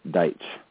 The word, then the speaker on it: Deitch: Not too many people get it right. It's Deitch. – Deitch, okay. And that's Dennis, D-E-N-N-I-S, and the last name, D-Y-C-H-E.